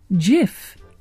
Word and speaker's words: GIF: The pronunciation is actually GIF, which is confirmed by GIF creator Steve Willite.